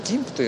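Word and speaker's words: GIMP: ギンプって読むんです。これ昔私ジンプって言ったんですけど、ギンプが正解なんですが、ギンプが X11 を必要としないネイティブアプリになりましたという記事でございまして、これはギンプというのは、有名な高機能画像編集アプリのフリーウェアです。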